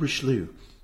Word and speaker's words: Richelieu: Like, so like old Richelieu. I think I said that right. – Yeah.